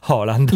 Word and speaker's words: Haaland: Si scopre che in Perù i peruviani pazzi per i mondiali di calcio si sono appassionati a alcuni calciatori, in modo particolare a Haaland, si pronuncia così, giusto? – Haaland, sì.